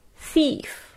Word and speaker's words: thief: 首先第一个是 thief 就是小偷的意思， T-H-I-E-F thief，特别注意一下 th 的发音，因为这个声音在中文里面没有，我们要怎么样发好这个声音呢？